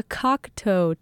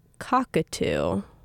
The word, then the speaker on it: Cocteau Twins: And the reason I'm playing them is because last week I played them for the first time, but the entire show I was pronouncing it Cockatoo. Which is really wrong. It's Cocteau. I was pronouncing it like the bird. I just didn't read it carefully enough, I guess.